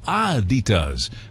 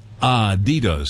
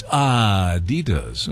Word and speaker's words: Adidas: It's supposed to be Adidas, with slight emphasis on the first syllable, the syllable, not Adidas, stressing the second. So it's Adidas, okay?